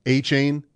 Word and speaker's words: Achane: That is how you say it, Achane.